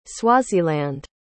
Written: Swaziland

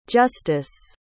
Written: justus

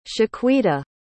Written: shaquita

